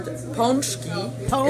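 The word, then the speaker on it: pączki: So the proper pronunciation of the word ponchki is in Polish. Ponchki is many, one ponchak. And you do not say punchkis or ponchki, but it's ponchki.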